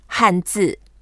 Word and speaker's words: 汉字: The way to say Chinese characters, there are two words, 汉字. – 汉字. – Han, that means the same word as Han Dynasty. 汉朝, 朝 means dynasty. So 汉字.